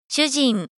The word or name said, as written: shujin